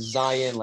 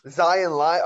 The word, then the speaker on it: Zion: It's Zion like lion. – Zion lion.